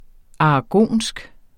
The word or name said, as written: aragonsk